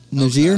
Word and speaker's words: Niger: Niger? Niger? Okay. – I'm glad you said that correctly. A lot of people don't.